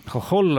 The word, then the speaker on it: Hohol: Hohol on aga slaavi H-tähega ja see on hääldamisel ka tugev H.